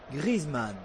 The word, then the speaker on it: Griezmann: Juan Griezmann. – No, si por mucho me lo pongas, o sea, lo puedes poner hasta el día del año nuevo. – Está bien pronunciado y con cariño.